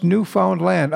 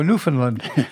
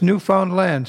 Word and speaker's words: Newfoundland: They're working up in Canada, and they're working on Newfoundland Island, and it's pronounced Newfoundland. Understand Newfoundland, because we way too often slur it and we go Newfoundland. And if you live there, it's Newfoundland.